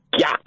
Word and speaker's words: GYATT: Yeah, am I even saying it correctly? Is it GYATT? – GYATT. Yeah, GYATT.